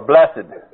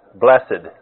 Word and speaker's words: blessed: And for those of you who care, you can pronounce that blessed or blessed. We looked it up in the dictionary to find out. In Old English, they used to divide it, blessed.